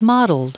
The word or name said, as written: mottled